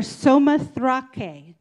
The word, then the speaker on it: Samothrace: So we read in Acts 16:11 through 15, from Troas we put out to sea and we sailed straight for Samothrace. It doesn't look like it sounds because I looked up the pronunciation.